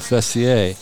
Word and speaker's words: Fessier: And when I married her, I changed the pronunciation of my name because I was Fessier, and she didn't like the way that sounded. She thought we should go with the proper pronunciation of Fessier.